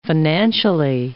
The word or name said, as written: financially